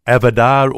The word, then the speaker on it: avodah: It's interesting that the Hebrew word avodah or avoda, depending on how you want to pronounce it, it's variously translated in the Bible as work, service, and worship.